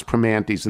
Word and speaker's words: Primanti's: Ask any local to pronounce Primantis, and they will say Primanti's.